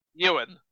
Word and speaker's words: Ioan: Ioan. Like Ewan McGregor.